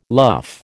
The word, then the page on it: luff: American English